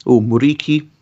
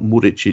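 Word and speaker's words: Muriqi: and which is, I think, is the correct way to pronounce it, actually. It's not Muric, it's Muriki, which is how the Kosovans say it.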